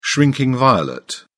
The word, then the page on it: shrinking violet: British English